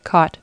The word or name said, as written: caught